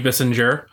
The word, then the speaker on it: Bissinger: Bissinger. I hope I pronounced that name right. – Bissinger. Yeah, I'm sure you did fine.